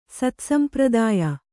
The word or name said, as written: ಸತ್ಸಂಪ್ರದಾಯ